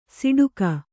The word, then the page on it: ಸಿಡುಕ: siḍuka